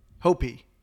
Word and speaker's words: Hopi: Specifically the Haupi Indians. Hopi. Sorry, I pronounced it wrong.